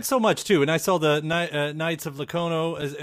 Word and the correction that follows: Lono: Am I saying that right? The comic that's on the way, too? I mean, Knights of Lono. – Lono, yeah. That's right.